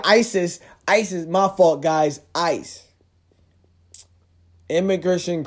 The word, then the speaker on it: ICE: ISIS, I C E, ISIS. Am I saying that right? Oh shit, I'm saying no. Oh. oh my fucking gosh! All right, guys, shoot me now. Pew. Ice. I've been saying ISIS for fucking what reason and shit.